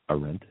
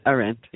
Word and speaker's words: Arendt: Hannah Arendt. – Arendt, yes.